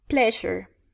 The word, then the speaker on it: pleasure: Mm, the word is pleasure. Pleasure. – Excellent. Pleasure. No plaisir, sino pleasure. Nuevamente, la S suena francés.